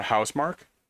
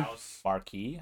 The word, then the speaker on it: Housemarque: And it just came out, what, today, that Sony just acquired, Finnish developer Housemark? Is that how you say it properly? – Housemarque, I guess. – Marque. – A little bit French or Finnish, whatever you call it. It's a Finnish...